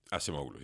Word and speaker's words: Acemoglu: a ver si lo digo bien, Acemoglu. – Acemoglu. – Sí.